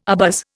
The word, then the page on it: abuzz: Indian